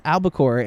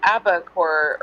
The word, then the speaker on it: Albacore: It's Albacore." And I was like, "Oh." – And he mispronounced it again in the okay. – Yeah. – Calls it Abacore or something.